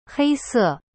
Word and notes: 黑色: hei se

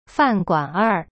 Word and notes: 饭馆儿: fan guanr

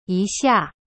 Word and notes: 一下: yi xia

